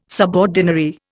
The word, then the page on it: subordinary: Indian